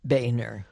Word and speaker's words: Boehner: Really, and I just want to make a slight correction, Joanne. The speaker's name is pronounced Boehner.